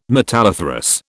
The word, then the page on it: metalliferous: British English